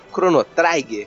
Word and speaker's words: Chrono Trigger: O jogo Chrono Trigger se fala como se escreve: Chrono Trigger. É uma invenção de quem gosta de falar difícil.